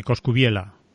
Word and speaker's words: Coscubiela: El otro día leí el sábado un artículo de Coscubiela, ¿verdad? Lo digo bien. – Sí, Coscubiela.